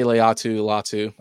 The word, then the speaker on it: Laiatu Latu: But we got Laiatu. Am I saying that right? Laiatu Latu? – I say Laiatu Latu.